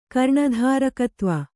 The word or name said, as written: ಕರ್ಣಧಾರಕತ್ವ